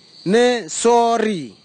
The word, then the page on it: nisauri: kwamera; Tanna